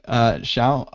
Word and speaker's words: Xiao: And Yunong Xiao.